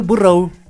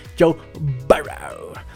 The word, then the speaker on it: Burrow: A ver ese mi Joe Burrow. Pues no, no, niñita, no. Pues no, pues no, no es burro, es Joe Burrow.